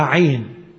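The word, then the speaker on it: عين (עין): هذه الأعداد الثمانية تأتي تحت حرف العين، وكلمة عين في العبري عين، اسمها عين، ينطقوها عين، والجميل برضو ان معناها عين، عين.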